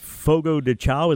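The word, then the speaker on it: Fogo de Chão: Fogo de Chao.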